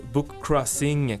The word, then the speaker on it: bookcrossing: Donc, pour plus d'informations, vous pouvez visiter le site bookcrossing, comme ça se prononce en anglais, .com.